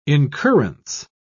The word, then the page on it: incurrence: British English